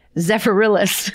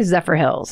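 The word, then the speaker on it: Zephyrhills: So Carl goes first, and then the wife and children follow after him, and they settle in, it's all one word, but it looks like it's pronounced Zephyr Hills, Florida. But it also could be Zephyrillis. Zephyr, no, it can't, because there's no other I. Let's just say Zephyr Hills.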